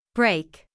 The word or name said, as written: break